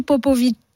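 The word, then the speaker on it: Popovici: Popovic, qui s'écrit Popovic. – Ouais. – Mais qu'on prononce Popovic.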